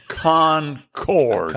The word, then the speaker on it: Concord: Raised in Concord, North Carolina. He graduated... – Now, wait a minute. – Concord. – It's Concord. – Concord. – Accent on both syllables.